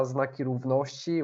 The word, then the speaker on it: Znaki Równości: And actually, as to the pronunciation of the name of this group, it's Federacja Znaki Równości, which means Signs of Equality Federation.